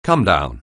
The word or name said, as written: comedown